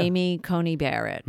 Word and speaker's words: Amy Coney Barrett: So Amy Coney Barrett, am I saying her name right? – Yeah. – Amy Coney Barrett, mm-hmm.